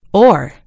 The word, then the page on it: oar: American English